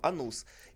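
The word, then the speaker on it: Anus: Анус, Анус, у него ударение на втором. Анус.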